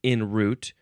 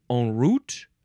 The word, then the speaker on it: en route: So I guess the proper French pronunciation would be en route.